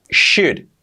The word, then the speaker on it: should: Should, importante la pronunciación, SH, y la L no se pronuncia. Should, should, should.